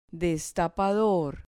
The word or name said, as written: destapador